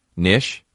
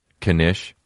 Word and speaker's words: knish: Knish. Nish? You pronounce the K? Knish.